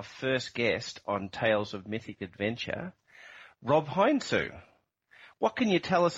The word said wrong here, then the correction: Heinsoo: That Mob, you just hit both syllables one notch off. It rhymes with ain't so. Heinsu.